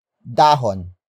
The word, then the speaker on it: dahon: The main topic is a phrase starting with dahon. Dahon. Dahon. Dahon is spelled as D-A-H-O-N.